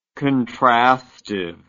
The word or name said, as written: contrastively